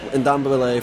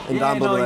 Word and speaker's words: Ndombele: I think Tottenham is, you know, they got that young guy Ndombele from, I just butchered that, Ndombele, yeah, no, from Lyon.